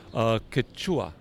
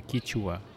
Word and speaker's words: Quechua: Yeah, Quechua in Peru, they say Quechua. In Bolivia also, they pronounce as Quechua. In Ecuador, some communities, they say Quichua. – Quichua. – With an I.